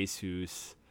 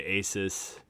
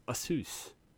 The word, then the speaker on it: Asus: when Tony and I went to school, it was, we would always say Asus. A lot of, some other people say Asus. But apparently, yes, Asus.